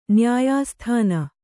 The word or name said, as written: ನ್ಯಾಯಸ್ಥಾನ